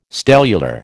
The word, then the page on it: stellular: American English